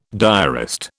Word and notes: diarist: British English

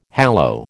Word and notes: hallow: American English

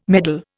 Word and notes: medal: Indian

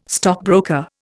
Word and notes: stockbroker: Indian

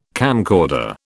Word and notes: camcorder: British English

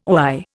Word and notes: oi: Indian

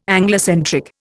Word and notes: Anglocentric: Indian

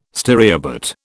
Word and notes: stereobate: British English

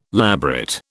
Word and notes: labret: British English

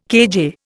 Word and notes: kJ: Indian

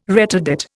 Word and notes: retardate: Indian

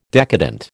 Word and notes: decadent: American English

